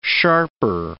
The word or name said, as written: sharper